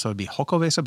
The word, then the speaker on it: Xocoveza: It's Xocoveza. So the Xocoveza? X, apparently, I looked it up and a couple of different people have said this. The X in X-O-C-O is pronounced with an S-H sound.